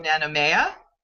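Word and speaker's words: Nanumea: several of your episodes, and I hope I'm pronouncing this right, called Tales from Nanomea. – Yes, wonderful.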